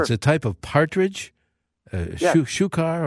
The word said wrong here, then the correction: chukar: or something like that. Is that how you pronounce it? – Chukar. – Oh, it is.